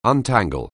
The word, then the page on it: untangle: anglais